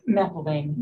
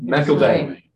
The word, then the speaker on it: McElvain: So we have Jessica McKelvin and Tumplin. Pronouncing that right or wrong? – McElvain. – McElvain. – Yes. – Thank you. – With the Irish spelling.